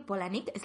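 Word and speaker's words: Palahniuk: Em, creo que Polanik siempre decía que era como mi abuela se llama Paula y mi abuelo Nick y entonces se pronuncia así porque yo lo he decidido, pero nunca he sabido cómo se dice porque whatever.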